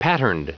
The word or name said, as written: patterned